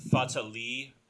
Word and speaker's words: Fatalii: pardon me, I was mispronouncing it because the text is small and my eyes are bad. The feta leil pepper, mm-hmm. F-A-T-A-L-I-I.